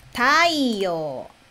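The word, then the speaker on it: taiyo (太陽): Se escrever assim, taiyo, taiyo, significa sol, sol. Então, sol é taiyo, taiyo.Yo-u, tai-yo-u, tai-yo, né? Então, pronúncia mudou, tai-yo.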